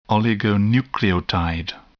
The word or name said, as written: oligonucleotide